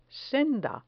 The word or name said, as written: senda